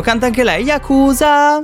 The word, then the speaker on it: Yakuza: Sarà Yakuza, è la classica pronuncia giapponese.